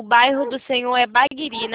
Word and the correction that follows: Barigui: né? – Barigui. – Barigui. Barigui, falei errado.